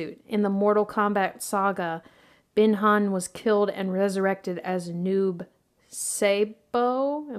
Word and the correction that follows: Noob Saibot: Am I saying that right? – Noob Saibot. I think that's how my friends always said it, but that was Logan, West Virginia friends, Noob Saibot.